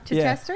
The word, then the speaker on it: Chichester: Is it Chichester? Nah. Let's spell it. C-H-I-C-H-E-S-T-E-R? Chichester? – Yeah. Chichester.